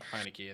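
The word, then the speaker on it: Heinicke: Is that how you say it? – I'll pull it up while we're right here. – I had him on fantasy for a long time, and I never knew how to say the dude's name. Is it Heineke? Heineke? – Heineke, I think.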